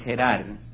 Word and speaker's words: Gerar: Isaac está en Gerar, como lo pronunciamos en español o castellano, en Gerar, que queda al sur, es siempre la parte sur.